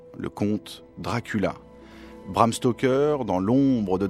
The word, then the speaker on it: Dracula: Dracula. The sound is so intriguing. Three syllables. Dracula.